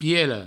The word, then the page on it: piela: pielo